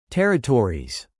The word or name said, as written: territories